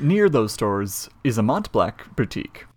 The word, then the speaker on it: Mont Blanc: I think it's Mont Blanc. – Mont Blanc? Yeah. – Because it's a French mountain. – Okay, fair. Mont Blanc. I feel silly saying that. Mont Blanc.